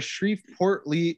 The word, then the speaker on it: Shreveport: Actually, now that we're kind of talking about weird stuff, I came across a news thing a couple weeks ago outside of Shreveport, Louisiana.